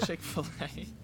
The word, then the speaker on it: Chick-fil-A: Chick-fil-A.